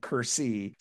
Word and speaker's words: Kearse: Curse, by the way, everybody, is K-E-A-R-S-E. – So it's funny. I was definitely going for the curse metaphor. I think I always pronounced it as like Cursey, Aaron Cursey.